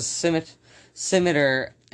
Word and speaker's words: scimitar: Yeah, it's a scimitar. And it's... – Scimitar. – Scimitar is the American pronunciation. Scimitar is the actual way you say it.